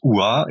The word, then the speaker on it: OOHAAH: C'est enfin ça se prononce OUA et ça s'écrit O O H A A H.